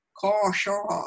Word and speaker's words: Kasha: I mean, even I'm so glad that people talked about the naming, because my name, Kasha, I can still hear my grandpa correcting me how to say it. Kasha. It's like, because of the barred A's.